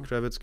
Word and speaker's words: Kravitz: Äh, die, ähm, genau, Zoe, was wird das ausgesprochen? Kravitz? Zoe Kravitz, genau, die auch äh, im Batman mhm.